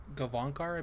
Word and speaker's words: Gavankar: I think, what is it, Janina Gavankar? I think she was in that. Am I saying her name correctly, Jared? – Sounded right to me. – Gavankar, I believe is how it's pronounced.